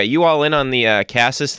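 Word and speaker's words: Casas: One thing I love about Cassis, and I wish it was just Cassis. I wish we could all just agree it's pronounced Cassis.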